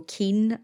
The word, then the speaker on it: caoin: So keen, as in, that's an anglicization of the Irish word C-A-O-I-N, which is pronounced the same way, keen, which means to cry.